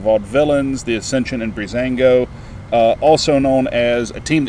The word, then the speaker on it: Breezango: Breezango, Breezango.